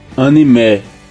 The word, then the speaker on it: anime: Ó, a pronúncia é anime. Não é nenhum e, é mais um é. Anime.